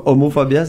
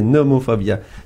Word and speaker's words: nomophobia: Donc euh, je le dis bien là, c'est pas homophobia, c'est nomophobia.